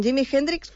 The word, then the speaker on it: Jimi Hendrix: Com és, a veure, en Jimi Hendrix, com es pronuncia? – Ho has fet molt bé.